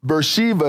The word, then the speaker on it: Beersheba: When Israel leaves, he's somewhere within this circle because he stops on his way to Egypt at Beersheba, Beersheba, or Beersheba as it is pronounced, and it is at the bottom left hand corner of that map prior to going into the land.